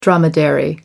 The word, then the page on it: dromedary: American English